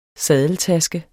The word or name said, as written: saddeltaske